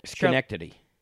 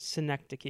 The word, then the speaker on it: Synecdoche: Schenectady. – No, it's pronounced Synecdoche. It's Charlie Kaufman's movie.